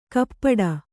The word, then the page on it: ಕಪ್ಪಡ: kappaḍa; Kannada